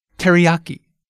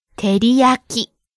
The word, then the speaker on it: teriyaki: Okay, first the English pronunciation. Teriyaki. See how we stress the ya syllable? – Ah. – Now can we hear the original Japanese pronunciation? – Teriyaki. – Each syllable gets the same amount of stress. Keep it even.